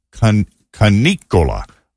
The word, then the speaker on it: canicola: And so a prolonged oppressive heat wave, sort of, you know, we call it the dog days of summer, is La Canicola. And it's spelled C-A-N-I-C-O-L-A.